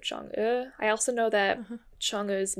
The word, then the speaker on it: Chang'e: I think it has to do with the fact that in Cantonese, Minnan and Hakka, which are all Chinese languages, Chang'e's name is pronounced, the second character is pronounced like Ngo. In Cantonese and Hakka, it's like Sung'o, and in Minnan, it's like Sheng'o, roughly something like that.